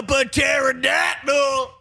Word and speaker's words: pterodactyl: Give me a pterodactyl. – Yes, sir. – Ah, home run. – Hey, why can't pterodactyls? Why can't you hear pterodactyls? – Because they got a silent P.